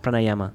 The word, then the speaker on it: pranayama: The pranayama? Pranayama, there you go.